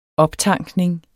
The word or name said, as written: optankning